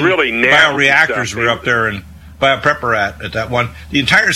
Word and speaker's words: Biopreparat: I mispronounce it. – Biopreparat, it's called. – Biopreparat.